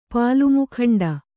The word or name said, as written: ಪಾಲುಮುಖಂಡ